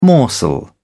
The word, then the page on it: morsel: British English